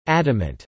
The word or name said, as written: adamant